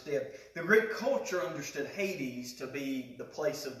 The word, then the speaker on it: Hades: The Greek term is Hades here, or Hades is how you pronounce it in Greek, but translated as hell in the King James Version.